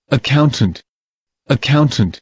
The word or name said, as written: accountant